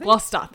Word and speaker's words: Gloucester: An armoured siege engine that was deployed unsuccessfully in the 1643 Siege of Gloucester. Wait, how do you say that? – Gloucester, I think? – Gloucester, that's it.